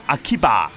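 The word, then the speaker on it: 秋葉: 而秋叶原日文嘅发音就系 Akihabara，不过由于太长嘅关系啦，当地人通常都叫佢做秋叶，亦即系 Akiba。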